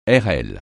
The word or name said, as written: airelle